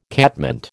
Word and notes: catmint: American English

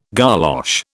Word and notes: golosh: British English

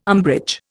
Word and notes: umbrage: Indian

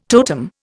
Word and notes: totem: Indian

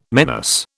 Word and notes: menace: British English

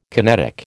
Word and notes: kinetic: American English

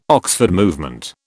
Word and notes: Oxford Movement: British English

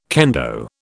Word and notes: kendo: British English

